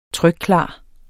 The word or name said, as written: trykklar